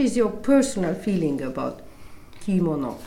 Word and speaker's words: kimono: Kimono. – Usually Western people put accent first, kimono. But kimono is not the correct pronunciation. We Japanese people pronounce it in a very flat manner.